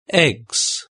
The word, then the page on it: eggs: British English